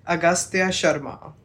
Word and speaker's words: Agastya Sharma: Agastya Sharma.